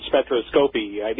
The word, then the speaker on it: spectroscopy: Is that actually in production at this point, or spectroscopy? That is how you pronounce it.